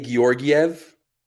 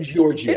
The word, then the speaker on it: Georgiev: Look, I know the announcers say Georgiev. – Do Those they, are G's. I'm yeah. saying Georgia. It's Georgia, and I'm sticking with it.